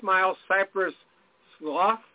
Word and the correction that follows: Slough: It's pronounced slough.